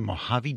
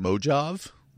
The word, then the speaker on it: Mojave: I have to go out to like the Mojave Desert because there's no exterior sound studios that I know of. – I believe it's pronounced Mojave.